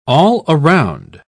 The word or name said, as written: all-around